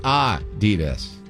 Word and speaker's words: Adidas: It's supposed to be Adidas. Adidas, with a slight emphasis on the first syllable, not Adidas.